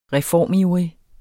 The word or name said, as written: reformivrig